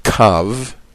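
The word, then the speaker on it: kav (קו): The word for line that measures around this vessel, what we would call the circumference, the word is kav, kuf, vav. That's how we pronounce it. But the way it's written in a Tanakh scroll, in a Malachim scroll, is kuf, vav, he. We don't pronounce the he.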